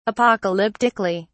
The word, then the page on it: apocalyptically: British English